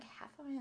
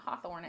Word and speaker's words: Hathorne: I'm like Hathorne. It's not Hawthorne.